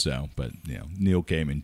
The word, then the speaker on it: Gaiman: So I saw a little piece of video of Neil Gaiman pronouncing his name. – Oh, really? – And it's Neil Gaiman. – Okay. – You know, not Gaiman, all this.